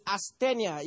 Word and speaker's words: asthenia: The Greek word is asthenia. It's spelled A-S-T-H-E-N-I-A. A-S-T-H-E-N-I-A.